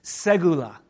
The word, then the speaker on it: segula: It's segula. Let's say that together. Ready? Segula. Segula. S-E-G-U-L-L is how you spell it in American.